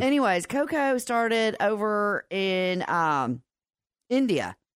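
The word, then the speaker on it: Kho-kho: No, Co-Co.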